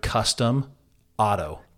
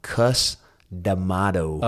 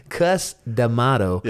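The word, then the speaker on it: Cus D'Amato: I thought, I don't know why I heard custom auto. – Customato. Let me pronounce a little better.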